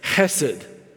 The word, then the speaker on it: hesed: and it's hesed. Hesed. And you know you're saying it right if you get a little bit of spit out, just a little bit, hesed.